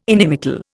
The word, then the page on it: inimical: Indian